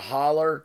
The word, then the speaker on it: hollow: However, a hollow gets to be pronounced a holler.